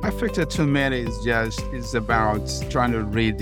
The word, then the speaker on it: affect attunement: In the next episode, you're going to share something called affect attunement. How do you pronounce that? – Affect attunement, yes.